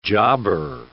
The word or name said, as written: jobber